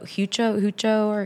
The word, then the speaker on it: Huico: But I would say, I don't know how you pronounce it. It's like H-U-I-C-O, Hucho.